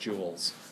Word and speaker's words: gules: G-U-L-E-S, pronounced jewels.